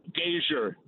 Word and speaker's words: Gasior: Gasior.